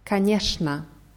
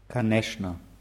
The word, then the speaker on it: конечно: Конечно schreibt sich mit че n in die Mitte, wird aber mit Eschan ausgesprochen. Kaneshna. – Kaneshna.